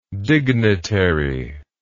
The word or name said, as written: dignitarial